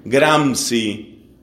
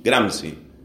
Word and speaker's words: Gramsci: Gramsci. Se escribe G-R-A-M-S-C-I, Gramsci, o Gramsci pronunciarán algunos, no sé cuál es la pronunciación usual en italiano para eso.